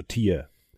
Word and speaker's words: tear: also Tier. Das Ding ist aber, dass das Wort Tier, wie es geschrieben wird, man kann es auch als Tear aussprechen und Tear ist ein Riss und einen Riss im Boden hast du im Spiel auch, sodass du in diese Unterwelt kommst.